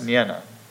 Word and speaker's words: Nienna: But in addition, he spent a lot of time with, I believe her name is pronounced Nienna.